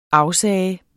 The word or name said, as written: afsige